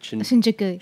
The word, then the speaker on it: Shinjuku: Shinjuku.